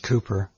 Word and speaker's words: Cowper: It's spelled C-O-W-P-E-R, but it's pronounced by the English Cooper.